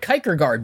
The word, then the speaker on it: Kierkegaard: I've read Kierkegaard before, so and that's how you say it.